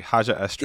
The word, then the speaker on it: Haja Estri: And even like the arc of him discovering that there's hope in the galaxy, where like you have Kamal's, I'm mispronouncing the name. – Estri, Haja Estri.